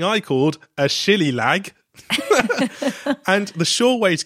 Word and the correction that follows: shillelagh: Tell you the number of people who have said their correct pronunciation is shillelagh or shillelagh, depending on where you're from.